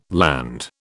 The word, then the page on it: Land: British English